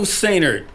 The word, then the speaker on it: Sehnert: My name is Joe Sehnert, S-E-H-N-E-R-T, Sehnert.